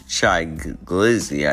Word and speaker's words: Shy Glizzy: So no, he is not going to speak at, the at, at, his um the title that he carries, Shy Grizzly, Shy Glizzy. I keep on saying his name wrong.